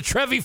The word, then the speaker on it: Trevi: Here it is a 1769 uh, uh, picture of it, hand drawn picture of the fountain de Trevi, Trevini, or Trevi, not Trevini, Trevi.